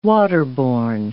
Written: waterborne

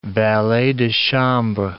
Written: valets de chambre

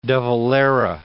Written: de Valera

